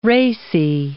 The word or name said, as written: racy